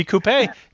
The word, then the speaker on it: coupe: It should be coupe. Check it out, Americans. Pronounce it coupe, goddammit.